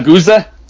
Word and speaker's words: Geuze: A Guza, but it's spelled G-E-U-Z-E.